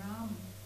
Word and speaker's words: Rama: Rahmen ist Rama, also der wird auch immer Rahm ausgesprochen in Indien. Das A wird immer weggelassen.